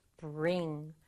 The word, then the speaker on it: bring: bring. bring이라는 단어가 있어요. bring, 두 박자 아니고요. bring. bring. 이렇게 한 박자로 발음해요.